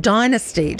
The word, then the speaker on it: Dynasty: All right, Catherine, do you remember Dynasty? – I do. – Oh, actually, the Americans say it Dynasty, don't they? – They do.